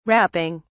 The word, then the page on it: rapping: British English